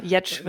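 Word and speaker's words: Giertz: ich, ich nenne sie jetzt, ich spreche sie deutsch aus, Girz. – Also, Girz, ja, Jetsch wird es, glaube ich, ausgesprochen, ja.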